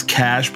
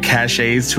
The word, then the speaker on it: caches: Caches. Whatever. Never pronounce that. – It's cash, but don't worry. I found that out after, as a young teen, accidentally saying cachets to a group of older D&D players I was running my first game for.